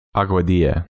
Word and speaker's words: Aguadilla: A-G-U-A-D-I-L-L-A. So the end is like quesadilla. – Aguadilla.